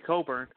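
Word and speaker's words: Cockburn: They added Kofi Coburn, which, by the way, I think the CK is silent just for FCC purposes.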